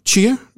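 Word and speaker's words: Tchia: Chia. Maybe you got to add a little small little T sound at the beginning.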